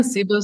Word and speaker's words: SIBO: Yeah, SIBO, SIBO, potato, potato.